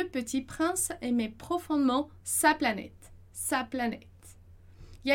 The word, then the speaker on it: planète: mas só para relembrar: planète, planètes. Então, esse T ali, ele é meio que mudo no final, né? Ele é muito sutil.